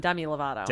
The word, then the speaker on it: Demi Lovato: Yeah, and among those were, as we said, Jennifer Lopez, Fall Out Boy, which we mentioned, talked to Demi Lovato. I always get her name wrong. – I think you're right the first time. Demi Lovato.